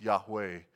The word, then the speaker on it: Yahweh: That name translated I am is pronounced Yahweh in Hebrew.